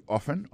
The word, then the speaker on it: often: Often Times, non, c'est O-F-T-E-N Times, T-I-M-E-S. Alors, c'est comme ça, on dit ça. Soit on dit often, often, non, c'est O-F-T-E-N, malgré l'écrit often, on prononce often.